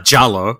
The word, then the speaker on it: Giallo: I better say it correctly because people always get on our case when we say it. Giallo.